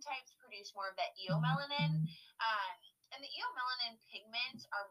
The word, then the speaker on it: eumelanin: It's eumelanin, right? – That's how I would say it, yeah.